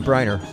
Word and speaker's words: Brynner: Lord Brynner, I think he named himself after uh, Yul Brynner, but he pronounces his name Briner when he sings it.